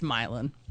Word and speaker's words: Milan: No, because he was from Milan. I know it's spelled Milan, but whatever. But in Indiana, where I'm from, it's Milan.